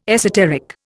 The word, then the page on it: esoteric: Indian